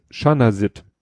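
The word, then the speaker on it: Chanasit: Der Hamburger Virologe Schmidt heißt Shanasit gesprochen.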